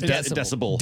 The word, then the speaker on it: decibel: One guy called in and said that his dad actually had a decimal reader. – Yeah, or decimal. Yeah, right. Yeah, right. – And so anytime it got, did I say it right? – You said decimal, but it's decibel.